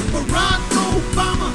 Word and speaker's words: Barack Obama: Barack Obama is how you say his name.